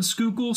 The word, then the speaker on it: Schuylkill: And we do not pronounce the first L. – Yeah, it's one of those deals where it's like you don't or the why? I said the word skookle so many times before I ever saw it written anywhere.